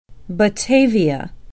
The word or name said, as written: batavia